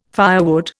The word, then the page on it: firewood: Indian